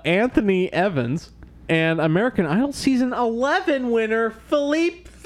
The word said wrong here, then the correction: Philip Phillips: No, it's Philip Phillips. Philippe. Philip.